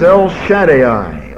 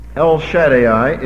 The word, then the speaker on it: El Shaddai: The name is El Shaddai, or El Shaddai. I've heard it pronounced both ways. Really makes no difference to me which you prefer. El Shaddai is the way I'm going to pronounce it tonight.